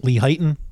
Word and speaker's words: Lehighton: Is that how you pronounce Lehighton? – Lehighton? – Lehighton. – Yeah.